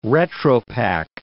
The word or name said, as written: retropack